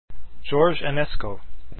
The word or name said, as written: enesco